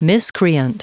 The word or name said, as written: miscreant